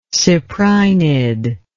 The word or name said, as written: cyprinid